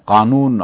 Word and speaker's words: قانون: یا کہ آپ نے وہ قاف کا تلفظ کرنا ہے، قانون آپ نے بولنا ہے